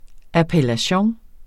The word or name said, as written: appellation